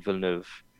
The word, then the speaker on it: Villeneuve: So this game was announced a few months ago, and the film hadn't come out yet, the latest film by Denis Villeneuve, which I really like, and I'm a Dune nerd.